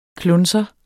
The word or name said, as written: KLUNSERE